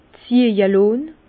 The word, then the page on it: ziehje lonn: Bas-Rhin; Herrlisheim